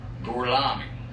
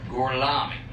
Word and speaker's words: Gorlami: Gorlami. – Mi scusi, con me? – Gorlami. – Ancora una volta. – Gorlami.